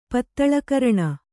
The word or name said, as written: ಪತ್ತಳೆಕರಣ